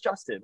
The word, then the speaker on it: Justin: And then there was other stuff like, the way people pronounce it, are you pronounced just 10? Like they would emphasize 10. I'm like, it's just Justin. Like, Justin.